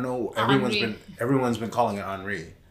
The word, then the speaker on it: Henri: I'm sorry, did you just French pronounce the hurricane? It's not Henry? – No, it's Henri.